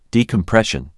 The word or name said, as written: Decompression